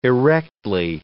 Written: erectly

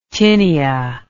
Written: tinia

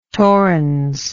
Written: torrens

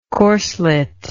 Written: corslet

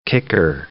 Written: kicker

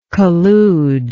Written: colluder

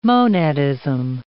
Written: monadism

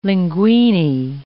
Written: linguine

